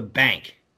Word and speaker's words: Banque: It's called The Bank, but it's spelled with a Q. It's like BANQUE or something like that.